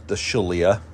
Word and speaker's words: Shaliah: We can look at the Shaliah, and it's pronounced S-H-A-L-I-A-H for my podcast listeners.